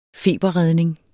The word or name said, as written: feberredning